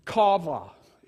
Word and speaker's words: qawvah: And that word for wait, I believe it's spelled Q-A-W-V-A-H. Kava is the word for it.